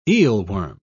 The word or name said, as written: eelworm